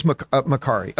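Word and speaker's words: Makkari: Some people in the live chat are saying it is Makari.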